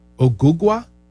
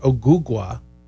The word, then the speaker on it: Ogugua: It's spelled O-G-U-G-U-A. Ogugwa? I believe would be how you would say it, Ogugwa, and so they shortened it to OG.